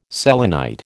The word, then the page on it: selenite: American English